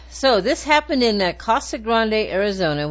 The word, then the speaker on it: Casa Grande: Casa Grande.